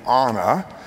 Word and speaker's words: Anna: When she accidentally hurts her little sister, Anna — the pronunciation is very important, I get corrected whenever I say Anna, it's Anna — their parents take the girls to seek the aid of the leader of magical forest-dwelling creatures.